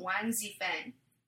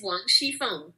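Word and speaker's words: Wang Zifeng (王熙凤): I just have so many feelings about Wang Zifeng. And I'm sorry that I'm, like, butchering your language. – You are. You are butchering it bad. It's Wang Zifeng.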